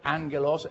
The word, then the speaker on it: angelos: And we have learned that the angel, the word angelos in the Greek, that's the way you pronounce it, angelos, A-N-G-O-L-O-S, angelos means messenger.